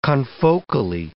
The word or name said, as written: confocally